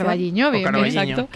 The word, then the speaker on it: O Carballiño: O Carballiño, no lo sé pronunciar. – O Carballiño, que también lo conoce muy bien, porque tu socia...